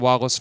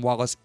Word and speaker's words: Wallis: If you want to reach out to me on Facebook at William Wallace for America, remember, last name is spelled W-A-L-L-I-S. Remember, William Wallace is for America.